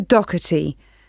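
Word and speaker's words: Docherty: Yes, it's Lisa Doherty. I'll spell that for you. It's D-O-C-H-E-R-T-Y.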